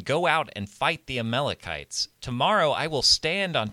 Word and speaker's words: Amalekites: apparently you can say it Amalekites, so that's what I'm going to say.